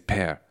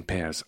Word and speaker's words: pear: Apples and pears, en anglais, ça s'écrit P-E-A-R, mais on prononce bien pear, comme bear, comme l'ours, hein, on ne dit pas oui, beer, oui. Comme la bière, l'ours, on dit bien un bear.